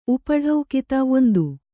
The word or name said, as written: ಉಪಢೌಕಿತ